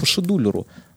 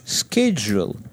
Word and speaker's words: scheduler: Действительно, это слово читается как шедулер,